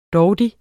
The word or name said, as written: DAUDI